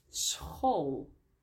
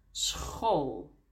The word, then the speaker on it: school: Maar soms hebben we CH, zo CH, combinatie, die we ook uitspreken als een G. Bijvoorbeeld, for example, in school. S-C-H, school. Of schattig, cute. S-C-H, schattig.